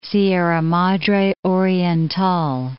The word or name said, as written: Sierra Madre Oriental